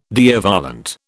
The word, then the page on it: Deo volente: British English